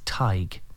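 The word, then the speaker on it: Tadhg: And it's Tig. It's like a tie you wear and put a G at the end. Tig.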